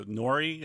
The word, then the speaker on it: nori: I mean, it's sort of like the nori, is that how you pronounce it?